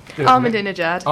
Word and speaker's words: Ahmadinejad: Yet there has always been critics of President Ahmadinejad. Uh, go on. – Ahmadinejad. – Ahmadinejad. I always think it, it uh, rhymes with i jacket.